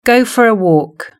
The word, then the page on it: go for a walk: British English